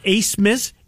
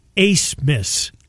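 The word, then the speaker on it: Abmas: The kid from Oral Roberts' pronunciation of his name, Max Abmus, it's pronounced Ace-mas, A-B, A-B, M-A-S, Ace Miss. – I wouldn't have got that one. – Nor would I. I mean, there's no C.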